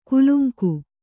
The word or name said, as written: ಕುಲುಂಗು